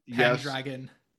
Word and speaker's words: Pendragon: Pendragon. – Yes. What did I say? – He said Pendragon. – I put the accent on the wrong syllable.